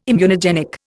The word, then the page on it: immunogenic: Indian